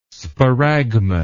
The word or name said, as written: sparagmos